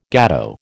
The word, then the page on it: gateau: American English